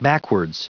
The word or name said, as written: backwards